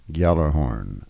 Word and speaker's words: Gjallarhorn: That's spelled G-J-A-L-L-A-R and then Horn, H-O-R-N. Gjallarhorn.